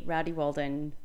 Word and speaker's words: Rowdy Walden: The indescribable but heaps funny Rowdy Walden has decided to join me. I've never said your name out loud. – It's a bit strange, isn't it? – Did I say it right? – You did.